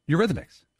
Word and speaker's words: Eurythmics: Am I mispronouncing it? – Eurythmics. – Okay. Eurythmics.